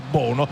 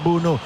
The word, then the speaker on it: Bounou: Completo verde per lui, ha due pronunce, Bounou o Bounou o Bono, così come ha scritto sulla maglietta, però insomma la pronuncia in lingua marocchina è Bounou.